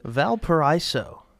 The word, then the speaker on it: Valparaiso: So, but then Youngstown State week one put up 52 on Valparaiso. I think that's how you pronounce it. – Valparaiso.